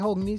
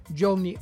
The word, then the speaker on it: Johnny: Se dice José, en cambio, el Hogni, no se dice Hogni, sino que se dice Johnny,